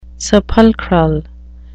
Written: sepulchral

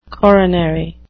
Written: coronary

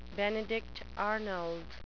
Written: benedict arnold

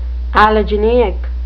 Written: allogeneic

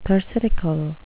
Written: thersitical

